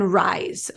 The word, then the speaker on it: Ryze: And then Rise, okay? Rise is spelled RYZE.